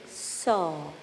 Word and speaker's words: Sau: It is spelled H-O-N-G. Exhaling, Saw. S-A-U. That's the Sanskrit word. The English meaning is, I am spirit. So you can use whichever is comfortable for you. Inhaling, Hong. Exhaling, Saw. Inhaling, Hong. Exhaling, Saw.